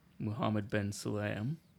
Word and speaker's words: Mohammed Ben Sulayem: The FIA president, Mohammed Ben Sulayem, I think I said it right this time.